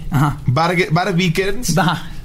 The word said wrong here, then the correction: Vikernes: Vikernes. lo vas, Vikernes.